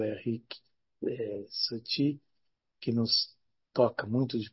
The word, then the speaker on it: Satie: Satie, se escreve Satie, Satie. Henrique Satie é a pronúncia no francês.